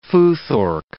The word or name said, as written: futhorc